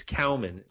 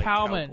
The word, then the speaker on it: Cowman: It's Cowman. Like Cowman. Cowboy, except Cowman.